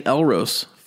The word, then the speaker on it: Elros: It rhymes with Elmo's. – Yeah, St. Elros.